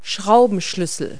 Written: wrenches

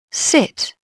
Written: sit

